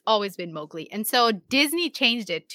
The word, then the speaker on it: Mowgli: According to the author Rudyard Kipling, it's pronounced Mowgli, like Mao like the cow, Mowgli.